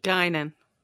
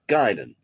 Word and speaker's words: Guinan: I mean, I know the Guinan, was that how you pronounce it? – Guinan. – Guinan, thank you.